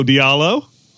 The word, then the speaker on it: Diallo: So Chris Fisher says, the Thunder are looking at Hamidou right now saying, mahalo, Diallo. That's pretty good. – What does that mean? – Mahalo means thank you in Hawaiian. and it happens to rhyme with Diallo.